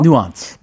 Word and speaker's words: nuance: 이거 어떻게 발음하면 좋을까요? – nuance. – nuance. nuance. 그쵸? 뉘앙스. 어허, 뉘앙스. nuance. 한국말로 뉘앙스.